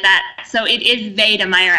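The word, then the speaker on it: Wedemeyer: So yeah, so I have to ask you, did I pronounce Maria's last name correctly? Maria von Wiedemeyer, or is it Wiedemeyer because it's German? – So it actually is Wiedemeyer, yes.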